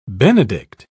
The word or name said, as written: benedict